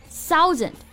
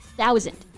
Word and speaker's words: thousand: So I hear some of you may pronounce it as thousand. No, it's thousand，没错，th 这个字母组合呢，我们要轻咬舌尖，不要发成了 s 的音。